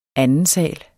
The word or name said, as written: andensal